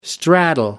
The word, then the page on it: straddle: American English